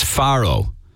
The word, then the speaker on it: Faro: It's Faro. A pharaoh is an ancient Egyptian ruler.